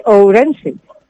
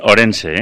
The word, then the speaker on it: Ourense: Pero en este caso es de Ourense. Bueno, lo he dicho bien. – Sí. No le digo. Pero escucha, muchos orensanos dicen Orense, ¿eh? Sin la U.